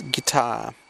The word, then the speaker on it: Qatar: In standard Arabic, the name is pronounced Qatar, while the local dialect is guitar. It sounds like guitar.